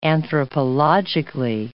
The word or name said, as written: anthropologically